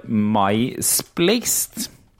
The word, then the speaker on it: Mysplaced: En av spillene som er med den gangen her, heter Eh, miss, uh, eh, det, det, ser ut som det står 'mysplaced'. – Ja. Hæ? – Det er misplaced, da. Misplaced, ja. Det uttales hvis 'misplaced', men det, ser, det staves m-y-s-p-l-a-c-e-d, altså 'mysplaced', da.